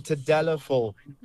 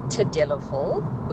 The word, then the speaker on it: Tadalafil: Hey Tabu, those two ingredients are called Sildenafil and Tadalafil.